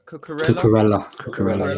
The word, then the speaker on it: Cucurella: Is it Cucurella? – Cucurella.